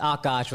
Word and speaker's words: Akash: Yeah, and they like, if they soften, like, it's Akash with like a really strong K.